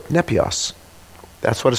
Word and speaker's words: nepios: There is a word, I'll spell it for you, it's nepios, N-E-P-I-O-S, N-E-P-I-O-S.